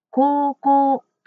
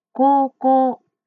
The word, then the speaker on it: 高校: うを入れると言いにくいので、高校と伸ばすように言ってみましょう。高校です。